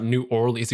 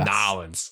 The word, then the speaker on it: New Orleans: Yeah, I've heard that from our resident friend Vector Cat. He's like, no, it's not New Orleans. He's like, what are you saying? You mean Nolens? – Nolens.